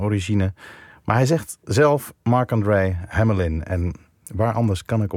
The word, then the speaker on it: Marc-André Hamelin: En zijn naam wordt dus op zijn Frans uitgesproken. Ja, ik hoor het heel veel mensen inderdaad zeggen. Marc-André Hamelin.